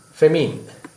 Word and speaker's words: famine: Say, for example, there are situations like when the Sri Lanka was going through certain feminine. Am I correctly pronounce it? Feminine, right? This scarcity of food. Famine, I think.